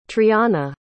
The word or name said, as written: trianna